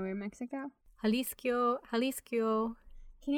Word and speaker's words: Jalisco: And the pronunciation you were looking for is Jalisco, Mexico.